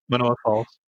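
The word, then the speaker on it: Mānoa: Manoa Falls? Did I say it right? – Manoa Falls.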